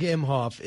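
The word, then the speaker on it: Emhoff: So Doug Imhoff is the second gentleman. Did I say that right? – Yes.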